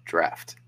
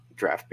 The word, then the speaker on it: draught: D-R-A-U-G-H-T. – Bang. Correct. And it's pronounced draft. Draft beer. – Drought. Drought beer. – No, draft beer.